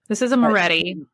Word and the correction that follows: Martelli: a Martelli. Martelli. I can never say the name right. It's Martelli.